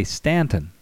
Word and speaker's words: Staunton: Just for your information, the locals here where we live, they say Stanton, but it's actually spelled Staunton, S-T-A-U-N-T-O-N.